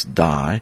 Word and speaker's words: dei: When we talk about this word must, it is the Greek word DEI, which we would pronounce die, number 1163 in the Strong's.